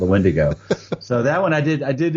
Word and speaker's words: Wendigo: Was it Wendigo, or how do you pronounce it? – It's the Wendigo, yeah.